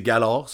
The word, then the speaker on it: galore: Fait que c'est Galore, c'est de même ça se prononce.